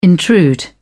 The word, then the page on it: intrude: British English